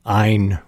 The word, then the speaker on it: Ayn: I think she pronounced her first name so that it rhymes with mine. Ayn Rand.